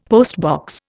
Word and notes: postbox: Indian

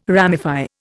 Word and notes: ramify: Indian

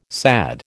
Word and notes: SAD: American English

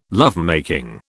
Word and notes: lovemaking: British English

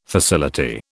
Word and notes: facility: British English

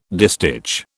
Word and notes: distich: British English

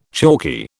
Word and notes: chalky: British English

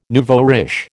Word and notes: nouveau riche: American English

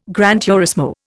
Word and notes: gran turismo: Indian

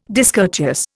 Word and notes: discourteous: Indian

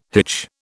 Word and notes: hitch: British English

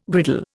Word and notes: griddle: Indian

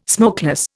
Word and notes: smokeless: Indian

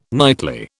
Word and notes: nightly: British English

